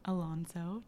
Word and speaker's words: Alonso: Alonso.